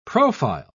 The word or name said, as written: profile